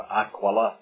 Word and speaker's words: Aquila: And he had found a Jew named Aquila, or Aquila is probably the correct pronunciation, but we'll not worry about that.